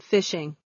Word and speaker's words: phishing: Personal information attacks are accomplished through an activity called phishing. Though it is pronounced like the word phishing, it is spelled with a PH rather than F.